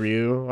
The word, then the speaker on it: Ryu: And I think it was the first time, I remember just really identifying with the actor who played Ryu, or as they constantly pronounce.